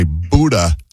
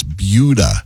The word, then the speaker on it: Buda: Buda. – Buda gets people a lot. – Yeah, people say Buda. – Yeah. – It's Buda.